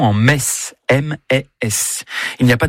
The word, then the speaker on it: Metz: Le meilleur moyen de mémoriser la prononciation correcte de la ville de Metz est certainement de se rendre au stade Saint-Symphorien, où vous répéterez jusqu'à plus soif. Ici, c'est Metz.